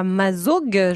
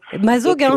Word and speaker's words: Mazaugues: Ghislain est avec nous à Mazaugues, je le dis bien. Bonjour Ghislain. – Oui, bonjour. – Vous allez bien ? – Enchanté, oui, très très bien, bien sûr. – Mazaugues, c'est comme ça qu'on dit.